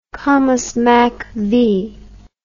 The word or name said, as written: comusmacv